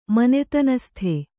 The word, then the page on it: ಮನೆತನಸ್ಥ: manetanastha